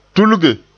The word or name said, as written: tulge